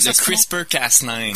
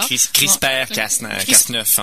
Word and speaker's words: CRISPR-Cas9: Donc, tout à l'heure, tu vas le prononcer mieux que moi parce que je ne sais pas comment le CRISPR-Cas9. – CRISPR-Cas9, voilà. – CRISPR-Cas9 en français.